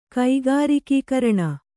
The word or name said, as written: ಕೈಗಾರಿಕೀಕರಣ